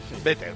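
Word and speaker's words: Better Love: Butter Love, ¿cómo lo he dicho? ¿Lo he dicho bien? ¿O Better? ¿Cómo se dice? Better. – Sí, tú has dicho amor de mantequilla, pero exactamente. Es Better. – Better.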